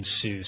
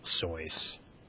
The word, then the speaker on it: Seuss: A Dr. Soy's collaborator named Alexander Liang wrote, "You're wrong as the deuce, and you shouldn't rejoice if you're calling him Seuss. He pronounces it Soy's."